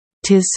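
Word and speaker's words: 'Tis: Tis so. Tis so. It is를 tis라고 발음하는 건 시적인 표현입니다.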